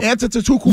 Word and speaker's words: Antetokounmpo: Giannis Antetokounmpo. – Is there? You see, can't pronounce that. That's an insult. To Milwaukee. That is an insult. – Antetokounmpo.